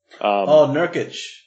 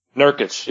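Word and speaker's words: Nurkić: And another one is, I think it's Yusuf Nurkic. I don't even know how you pronounce his name, but the guy from Portland. – Nurkic. – Nurkic, yes, thank you.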